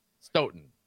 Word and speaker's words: Stoughton: Stout. You know, when in doubt, go to Stout. We're lacrosse guys. Okay. Stoughton, right? Stoughton. There you go. – Yeah, Plumber. Well, Stout doesn't have a GH in there.